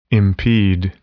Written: impede

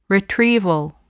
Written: retrieval

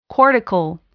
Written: cortical

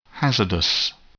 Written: hazardous

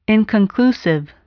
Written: inconclusive

